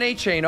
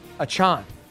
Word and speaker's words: Achane: Don't call him Devon Achane. Oh, no. Start calling him Devon Achane. Hope I got that right.